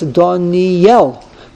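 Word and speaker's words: Daniel (דניאל): I'm sorry, the Aleph of Daniel, it's not pronounced. The emphasis goes on the Yud, Nocha, ki hatserita chasayud v'hayud gusha. So therefore, when you pronounce his name, it's Daniel. The emphasis is thus less.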